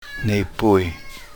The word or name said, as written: nepuy